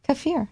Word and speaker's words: kefir: Kefir.